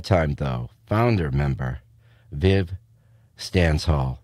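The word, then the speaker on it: Stanshall: Viv Stansall, I'm going to say this wrong every time. It's Stansall.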